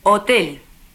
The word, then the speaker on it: hotel: Como sabes, en español la H no se pronuncia nunca. Escucha y repite las palabras siguientes. Hija. Hospital. Hotel.